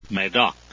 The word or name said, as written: medoc